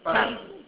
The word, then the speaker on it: párrafo: Párrafo. – Párrafo. – Párrafo. Párrafo. No, párrafo. It's emphasis on that pa.